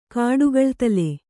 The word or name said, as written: ಕಡುಗಳ್ತಲೆ